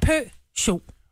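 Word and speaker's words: Peugeot: Det udtales pølse, chokolade. – Ja. – Pø-cho. Bare tænk på pølse og chokolade.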